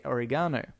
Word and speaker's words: oregano: And it was meant to be a joke that she's mispronouncing it. – Yeah. – But in America, that's how everybody pronounces it. Yeah, so it was obviously very specifically aimed at Australia who say oregano.